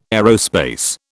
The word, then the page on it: aerospace: British English